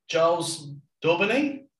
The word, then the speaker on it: Giles Daubeney: So welcome to the RecWired podcast, and we've got recruitment royalty today, Giles Daubeney.